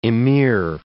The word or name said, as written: amir